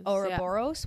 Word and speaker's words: Ouroboros: In alchemy, we find, I don't know how to say this correctly, but it's Ouroboros. – Ouroboros. – Ouroboros, yeah.